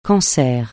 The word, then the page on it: cancer: francais